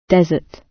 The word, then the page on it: desert: British English